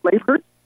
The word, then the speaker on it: Lebherz: Tia, am I pronouncing your last name correct, Lebherz? – You are.